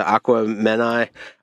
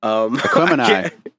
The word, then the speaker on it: Aquemini: Aquamenai. – Aquemini.